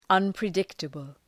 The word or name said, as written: unpredictable